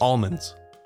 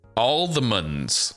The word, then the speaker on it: almonds: Sorry, chocolate with almonds. I pronounce the L. Almonds. – All the muns. All the muns. Not munds.